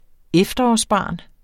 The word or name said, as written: efterårsbarn